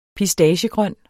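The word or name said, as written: pistaciegrøn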